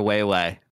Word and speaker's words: Wei Wei: And it's a wee wee or way way. It's W-I. – W-I? – Yeah, that's how it's pronounced. Like, way, way.